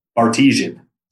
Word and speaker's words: Bartesian: Is it called the Bartisan Board? Did I say that right? – Bartesian. – Sorry, Bartesian.